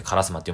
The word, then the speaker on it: 烏丸: カラスに丸と書いてカラスマって読むんですけど、実はこれカラス関係ないと言われてるらしいんですよ。